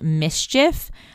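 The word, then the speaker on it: MSCHF: The shoes are produced by a company called Mischief, which is spelled M-S-C-H-F, a group known for creating viral and yet controversial products.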